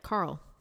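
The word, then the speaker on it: Qarl: Yeah, Carl, spelled with a Q. – Okay, spelled with a Q. – Yeah, it's Q-A-R-L.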